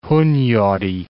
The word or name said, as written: Hunyadi